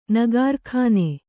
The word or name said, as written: ನಗಾರ್ಖಾನೆ